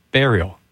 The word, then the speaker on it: Burial: It's going to pronounce it like Burien, the city just south of Seattle, but it is, in fact, Burial, not Burial.